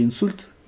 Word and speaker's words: инсульт: У нее случился инсульт. Или инсульт? – Инсульт.